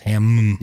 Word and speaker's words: Hamm: There's two M's. I think it's pronounced Hamm. – You are correct.